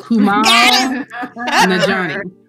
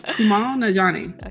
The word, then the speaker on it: Kumail Nanjiani: Kumail Nanjiani. Yeah. Kumail Nanjiani.